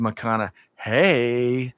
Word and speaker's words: McConaughey: Now, I got to add a little something there, because as Amanda, my dear Amanda here, the better half, she's taught me how to pronounce his name correctly, and it's Matthew McConaughey.